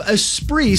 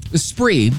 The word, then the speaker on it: Esprit: Esprit, which, if you never know how to say it, is spelled E-Spirit.